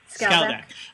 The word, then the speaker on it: Skaldak: talk to Zaldak. Is that right? Did I say that right? – Skaldak. – I'm like, wait a minute, that's not right. I was thinking Zhukov and said Zaldak.